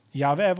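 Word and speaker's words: Yahweh (יהוה): Eentje is, weet je hoe je hem kunt uitspreken? Als je woorden pakt, en heel vaak gebruik je je lippen en je tong, hè? Maar als je Yahweh, als je erover nadenkt hoe je het kunt uitspreken, is als volgt. Yahweh. Je kunt het uitspreken zonder je tong en je lippen op elkaar te doen.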